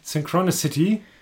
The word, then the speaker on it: Synchronicity: Synchronicity. – Synchronicity. So ist es ein bisschen flüssiger ausgesprochen.